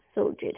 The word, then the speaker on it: Sulgit: Suljit. Hey, do you spell it S-U-L-G-I-T? – Yeah, that's right, yeah.